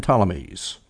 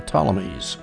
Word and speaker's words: Ptolemies: Ptolemies. Or if you prefer the anglicized pronunciation, War Between the Seleucids and the Ptolemies.